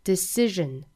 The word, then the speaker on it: decision: 后面半句我们要注意一个词叫做 decision decision 这个词我们看到有一个辅音、啊、就是这个字母 s 所代表的辅音应该是读成一个浊辅音的 r 的发法、啊、浊辅音 sh 或者是 r 都是需要翘舌的区别的话呢就是清辅音 sh 是一个送气音而浊辅音 r 是一个震动音、啊、我们需要这个声带震动发出这个 r 的发音 decision decision Can't let nobody make that decision for you.